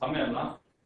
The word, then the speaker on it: PAMELA: In October 2008, the failed quantum matter exploration and light nuclear astrophysics, which should be pronounced PAMELA, has reported the sharp rise.